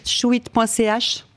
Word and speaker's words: shewit: Vous pouvez tout retrouver chouitte.ch. – Vous très bien. – Tout. – Et ça s'écrit, ce qui n'est pas forcément évident, S-H-E-W-I-T.